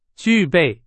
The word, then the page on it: 具备: Chinese